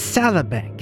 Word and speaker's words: Salabank: It's Salabank. – Can you spell that for me? – S-A-L-A Bank with three Q's.